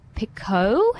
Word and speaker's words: Picoult: And it's called Leaving Time by Jodi Picoult. Have I learned how to say it now? – Yes! – After listening to No Filter, and I'd been saying Picoult for like ever.